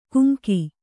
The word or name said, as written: ಕುಮಕಿ